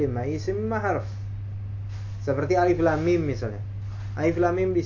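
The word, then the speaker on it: alif lam mim (الم): Karena dibacanya dibaca huruf. Bukan dibaca alam. Atau alam ro, alamr. Enggak, tapi bacanya alif lam mim ro.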